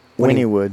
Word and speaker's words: Wynnewood: Wynnewood.